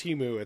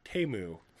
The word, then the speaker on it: Temu: And I learned that it's not pronounced Timu, it's Temu.